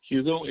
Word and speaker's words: Hugo: Hugo, H-U-G-O. – Mm-hmm. Last name? – A-R-G-U-E-T-A.